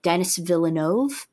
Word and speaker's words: Denis Villeneuve: He very much reminds me of the director, Denis Villeneuve, I believe I'm pronouncing that correctly.